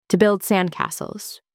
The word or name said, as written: to build sandcastle